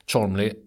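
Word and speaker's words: Cholmondeley: Cholmley, det uttalas bara Cholmley, det stavas med en jävla massa konsonanter.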